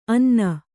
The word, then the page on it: ಅನ್ನ: anna